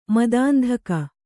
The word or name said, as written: ಮದಾಂಧಕ